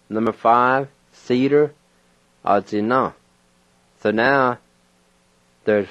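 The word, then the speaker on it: atsina: A-zi-na. A-zi-na.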